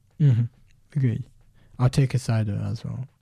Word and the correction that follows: Caicedo: How do you say it in Spanish? – Moises Caicedo. – Caicedo.